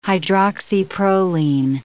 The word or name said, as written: hydroxyproline